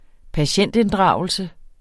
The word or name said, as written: patientinddragelse